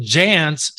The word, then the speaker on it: Jans: I'm with Michael Jans, spell it J A N S.